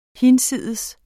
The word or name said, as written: hinsides